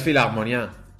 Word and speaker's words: Philharmonia: filagmonia o, como, como, se diga. En teoría es filagmonía. Filagmonía. con un pequeño. – hay con una abierta, Agudo.